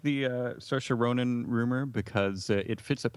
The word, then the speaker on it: Saoirse Ronan: Oh, Saoirse Ronan.